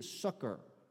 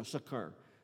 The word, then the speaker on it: succor: For in that He Himself hath suffered, being tempted, He is able, King James English, is actually pronounced, this word is pronounced, it's an old English word, it's actually pronounced to a degree, succor. So you have to kind of say it with a little bit of emphasis, succor, like that.